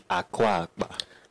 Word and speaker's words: Akwa Akpa: Now, before the market was formally established, Calabar, then known as Akwa Akpa, that pronounce it right? – Akwa Akpa.